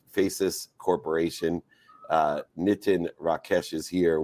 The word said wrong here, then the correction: Mphasis: It's Nitin, and the company is pronounced Emphasis. It's the emphasis without the E.